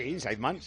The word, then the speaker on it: Inside Man: Desde dentro, vamos a decir que la gente, en español es desde dentro, pero realmente es Inside Man, ¿lo he dicho bien? – Sí, Inside Man, sí, sí.